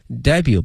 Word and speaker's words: debut: 英文有一个专门词叫 debut，D-E-B-U-T，但同学们注意了，这个后面这个 T 呢是不发音的，是 make something 或 someone's debut，表示首次亮相、首次登场的意思哈。